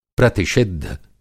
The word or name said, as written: प्रतिषिद्ध